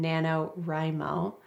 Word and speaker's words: NaNoWriMo: Because the most important thing, and I've never participated in NaNoWriMo. Right, that's how you say it? – Yes. – Okay. I was just, Abby was just correcting my pronunciation of it.